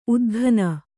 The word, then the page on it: ಉದ್ಘನ: udghana